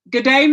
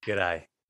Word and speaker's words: G'day: Is it "G'day, mate"? – G'day. G'day. G'day, g'day mate. G'day, mate. – G'day, mate. The least amount of syllables as possible.